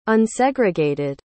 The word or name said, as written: unsegregated